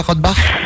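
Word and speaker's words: Rothbach: Tu habites à Rottbach. – C'est ça. – Ça se prononce comme ça, Rottbach ? – Rottbach, oui.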